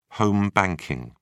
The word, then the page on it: home banking: British English